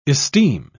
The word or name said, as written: esteem